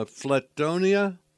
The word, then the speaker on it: Flatonia: Chip has offices in Austin, uh, Schulenburg, uh, Flatonia, and Hallettsville.